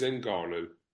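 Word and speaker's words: Ngannou: Why do you call him Nagano? – Oh, I probably... Am I pronouncing his name wrong? – Well, Nagano. I don't know. I thought... Well, we pronounce it as in we, as in I've never heard him be called in Nagano. I thought it was Nganu.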